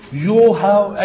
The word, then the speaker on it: يهوه: هم معظم العلماء قالوا لا ده بيتنطق كده يهوى، ممكن يتنطق يوها اي طريقة تانية يعني، فهم قال لا غالبا هو ده نطق الصح.